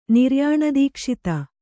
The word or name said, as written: ನಿರ್ಯಾಣದೀಕ್ಷಿತ